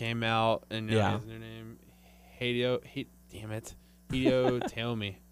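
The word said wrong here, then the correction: Hideo Itami: I believe it's pronounced Hideo Itomi.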